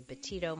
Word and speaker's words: Betito: You can connect with me on social media, on Instagram and Twitter and Facebook, at Dr. Lori Betito. My last name is spelled B-E-T-I-T-O.